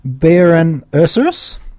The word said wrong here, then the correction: Borean Ursus: I'm pretty sure it's Borean Ursus.